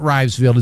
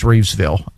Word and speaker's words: Rivesville: It is not Rivesville, it's Reevesville. And yeah.